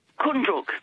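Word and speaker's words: Koondrook: Is it Kundruk, the pronunciation? – Kundruk. – Kundruk.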